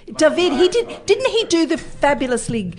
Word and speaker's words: David: I'm saying David. – Yeah, all right. You don't pronounce the D, you ignoramuses.